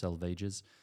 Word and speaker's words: Salvages: So with East Coker, which is, so it goes Bert Norton, East Coker, the dry selvages, which looks like salvages, but it's pronounced selvages.